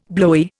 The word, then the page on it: blowy: Indian